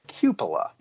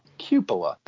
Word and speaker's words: cupola: I had to triple check how you pronounce this. Cupola. Cupola. That's that little dome that you see at the forefront when you look at the exterior of Shibe Park.